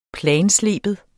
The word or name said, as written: planslibe